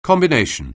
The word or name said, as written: combination